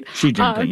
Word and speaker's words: Xi Jinping: However, CNN was supposed to be the pool reporters for television last night with Chinese President Xi Jinping. I hope I said that right. – Xi Jinping, yes.